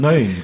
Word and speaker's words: Nguyen: Das spricht man nicht, das spricht man nicht in Nguyen, das spricht man Nein, glaube ich.